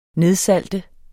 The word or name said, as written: nedsalte